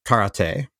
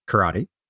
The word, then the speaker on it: karate: So you say karate in Japanese, meaning empty hand. But karate is really, but Americans say karate. Mm-hmm. So if I say karate, it sounds pompous and people don't know what I'm saying. So I'm better off pronouncing it wrong and having people know what I mean.